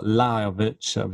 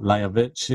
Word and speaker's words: Lajovic: Thought an interesting one on the, the next one down was uh, Lajovic or Lajovic, which is probably the correct pronunciation, uh, against uh, Egor Gerasimov, which obviously yeah.